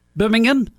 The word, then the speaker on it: Birmingham: You have to say it like that. Birmingham. – It ain't Birmingham. – Birmingham, because that's in Alabama. – Uh-huh. – That's the difference. – Yep. – This is located in central England.